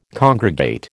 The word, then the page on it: congregate: American English